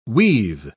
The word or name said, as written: weave